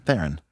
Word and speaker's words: Theron: Charlize Theron. – Theron.